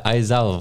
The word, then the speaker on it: Aizawa: En volgens mij spreek je het uit als Aizewa. – Ja, Aizawa volgens mij.